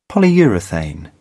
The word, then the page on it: polyurethane: British English